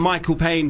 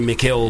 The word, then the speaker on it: Mikill Pane: I'm Michael Payne, but I spell it in a weird way, so people call me Mikhail.